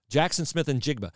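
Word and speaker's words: Jaxon Smith-Njigba: It's Jackson Smith and Jigba. And I asked how to pronounce his name, and that is how I was told to pronounce it. Jackson Smith and Jigba from Rockwall, Texas.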